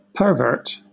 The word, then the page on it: pervert: British English; noun